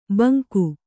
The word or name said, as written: ಭುಂಗಾ